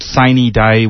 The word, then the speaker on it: sine die: Well, both the House and Senate have adjourned, as I said, sine die, which is the Texas pronunciation of a Latin phrase that means without a day.